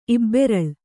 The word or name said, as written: ಇಬ್ಬೆರಳ್